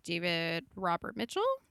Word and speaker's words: David Robert Mitchell: David Robert Mitchell.